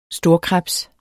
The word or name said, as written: storkrebs